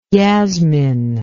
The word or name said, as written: yasmin